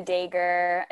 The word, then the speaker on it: Daghir: It's Fiona Dagher. It's spelled D-A-G-H-I-R, my last name.